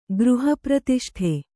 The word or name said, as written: ಗೃಹಪ್ರತಿಷ್ಠೆ